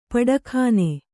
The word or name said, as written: ಪಟಕ್ಕನೆ